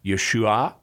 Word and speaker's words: Yeshua: In Aramaic, the term for his name would, depending on the dialect and the accent, would have been pronounced something like Yeshua.